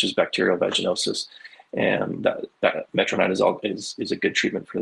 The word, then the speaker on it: Metronidazole: And then there's one more that I don't know if I'm saying it right. Metronidazole, is that right? – Metronidazole. Yeah.